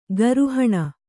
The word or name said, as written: ಕೋರಿಹಣ್ಣು